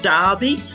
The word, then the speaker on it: Derby: I am coming to you from Derby, even though it's spelt like Derby.